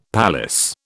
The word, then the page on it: palace: British English